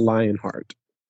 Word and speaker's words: Leonhart: His name is Squall Lionheart.